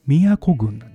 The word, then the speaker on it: 京都郡: でもこれ京都って読まないんですよ。宮古郡なんですよ。